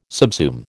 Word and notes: subsume: American English

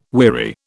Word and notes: weary: British English

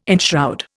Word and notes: enshroud: Indian

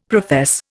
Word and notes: profess: Indian

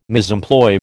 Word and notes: misemploy: American English

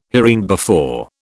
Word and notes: hereinbefore: British English

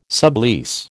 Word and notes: sublease: American English

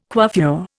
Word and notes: coiffure: Indian

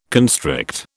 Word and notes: constrict: British English

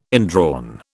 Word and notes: indrawn: British English

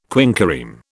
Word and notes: quinquereme: British English

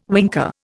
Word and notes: winker: Indian

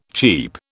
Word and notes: cheep: British English

